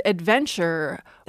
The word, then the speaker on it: adventure: So the word adventure had no D when we borrowed it from French, put the D back in to show its Latin etymology, and we, being well-behaved speakers, put the D back into our pronunciation.